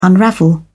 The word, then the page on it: unravel: British English